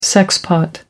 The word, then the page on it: sexpot: American English